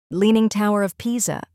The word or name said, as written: Leaning Tower of Pisa